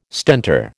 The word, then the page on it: Stentor: American English